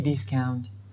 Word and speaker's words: discount: 好的，那么这个比如说啊，你在一些跳蚤市场买东西啊，或者是在一些市场上买东西，你觉得这个地方可以砍价，你说你能不能便宜一点，你可以问他 any discount， any discount， OK 啊，就是我们说的有任何的折扣吗？折扣这个词 discount 啊，ou 的发音呢一定要是 ow，一定是饱满的元音哈。